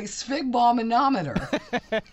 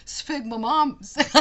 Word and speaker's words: sphygmomanometer: You said it correctly. Sphygmomanometer. And most people say, I know Ross and I talked about it because we didn't thought about it. Both of us have always said sphygmomom.